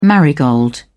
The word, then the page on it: marigold: British English